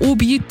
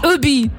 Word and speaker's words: Obi: Und das ist keine Abkürzung, aber Obi, das ist einfach nur Hobby auf Französisch ausgesprochen. Obi.